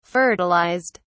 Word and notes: fertilized: British English

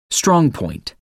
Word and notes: strong point: American English

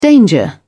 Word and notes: danger: British English

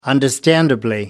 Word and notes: understandably: British English

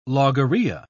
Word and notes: logorrhoea: British English

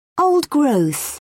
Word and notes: old growth: British English

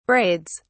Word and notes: braids: British English

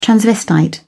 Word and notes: transvestite: British English

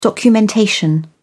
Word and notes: documentation: British English

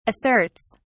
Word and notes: asserts: British English

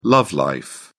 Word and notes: love life: British English

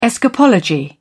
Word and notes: escapology: British English

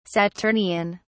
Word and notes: saturnian: British English

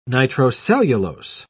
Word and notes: nitrocellulose: British English

